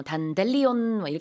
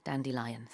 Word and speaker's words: Dandelion: 근데 가끔 요거 발음 잘못 하는 경우 제가 본 적이 있는데 단델리온 이렇게 발음하는 거 본 적 있거든요. 영어로는 Dandelion입니다. Just like a lion, 사자 같은 그런 발음이에요. Dandelion.